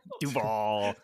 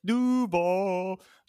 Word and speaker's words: Duval: How do you say Duval? – Duval. It reminds me of Jacksonville Jaguars. Duval.